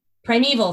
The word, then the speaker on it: Primeval: Primeval, thank you.